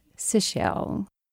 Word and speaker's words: Sichel: Calling in from New York is a Dr. Howard Sichel. Please tell me I'm saying your name right. – You said it perfectly. It's like seashells by the seashore.